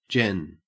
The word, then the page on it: gen: Australian English